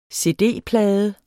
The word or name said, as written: cd-plade